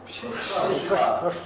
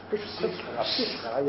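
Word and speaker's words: Przysucha: How do you say it? – Pshishcha. – Pshishcha, yeah.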